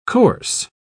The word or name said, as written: coarse